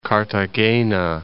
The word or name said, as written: Cartagena